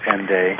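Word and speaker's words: Hendaye: Hende, which is interesting because usually the H is silent at the beginning of words in French, so it means Hende, or it's pronounced Hende.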